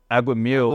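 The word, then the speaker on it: aguamiel: It's agua miel, which means in Spanish, water, honey. So agua miel.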